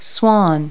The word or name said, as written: swan